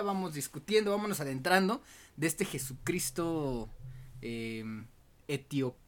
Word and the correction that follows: etíope: E, etiope. ok. Etíope.